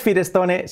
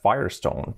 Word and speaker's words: Firestone: Vale, no es Firestone, se escribe Firestone, pero ¿cómo se pronuncia? Pues la pronunciación es, fijaos que es Firestone. Fire, fuego y piedra. Firestone. Firestone. No tiene nada que ver.